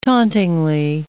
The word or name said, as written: tauntingly